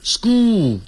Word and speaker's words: school: 因为 school 后头有个 L, S-C-H-O-O-L, 所以那个 L 要舌尖要抵上去。 School. 像念 school, 再来欧洲的 O, 但是那个舌尖是抵上去的。 School. 像 girl, 女孩子 G-I-R-L, 不要念成 girl, girl, 叫 girl, girl, school. School, 好，这种发音要特别记住。